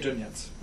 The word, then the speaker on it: Dornietz: I tell people however they want to pronounce it, they can. Jerry Dornietz. – Dornietz. – As long as you don't try and make it French, it's fine.